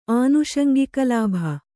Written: ಆನುಷಂಗಿಕಲಾಭ

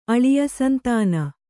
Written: ಅಳಿಯಸಂತಾನ

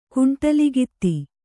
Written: ಕುಂಟಲಗಿತ್ತಿ

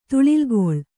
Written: ತುಳಿಲ್ಗೊಳು

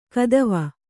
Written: ಕದವೆ

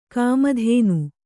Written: ಕಾಮಧೇನು

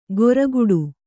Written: ಘೊರಗುಡು

